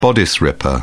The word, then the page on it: bodice ripper: British English